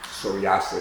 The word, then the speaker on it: psoriasis: Psoriasis is how it's pronounced here.